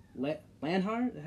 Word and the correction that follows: Lahiri: Lahiri. – Lahiri, yep.